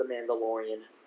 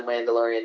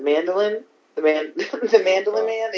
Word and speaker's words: Mandalorian: I explained it, The Mandalorian, to my morning show guys, and one of them was like, "The mandolin man." They just kept saying it wrong the whole time.